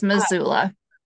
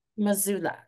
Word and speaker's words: Missoula: Is from Montana, where she raised two children in the beautiful and quirky Missoula Valley. I hope I said it correctly. – Yes, Missoula. – Missoula.